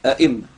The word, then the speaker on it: أئمة: ثم نقلت كسرة الميم إلى الهمزة وغيرت وأصبحت هكذا وأدغمت الميم، يعني نقلت كسرة الميم الأولى إلى الهمزة الثانية فكسرت الهمزة بعد أن كانت ساكنة، ثم أدغمت الميم الأولى في الثانية فنطقناها هكذا أئمة، عشان بس ما حدش يتصور إن هذه الهمزة همزة استفهام.